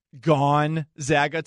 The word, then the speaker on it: Gonzaga: And I think you may have noticed that I have been over pronouncing gone Zaga today. It's because one person respond responded. I don't care who you root for, as long as you say it correctly. Gone, not gun. Zag, like bag.